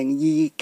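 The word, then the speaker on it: 食其: 上次都有提过呢，力而基佢个名而基啊，若果写出嚟呢就等于食同埋奇。不过呢要读呢就读成而基嘅。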